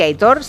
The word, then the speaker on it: Aitor: Es un oyente que dice, por favor, que Aitor se pronuncia como se lee.